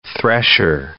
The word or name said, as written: thresher